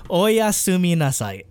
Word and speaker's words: oyasumi nasai: Oyasumi nasai.